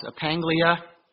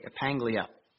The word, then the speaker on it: epaggelia: It's pronounced epaglia, E-P-A-G-G-E-L-I-A, epaglia.